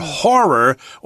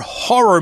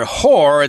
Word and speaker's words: horror: So we need to say it was a great year for horror or horror movies.